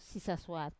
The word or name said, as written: sisasuat